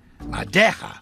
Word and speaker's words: madeja: Pronounce madeja. Put it together and say it fast, and it sounds like no me ha dejado.